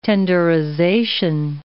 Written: tenderization

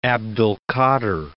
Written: Abd al-Qa.dir

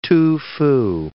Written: Tu Fu

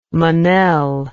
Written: menel

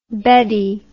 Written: beddy

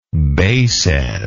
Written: basad

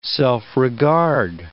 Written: self-regard

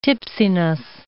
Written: tipsiness

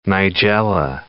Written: Nigella

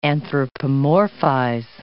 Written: anthropomorphize

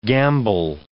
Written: gambol

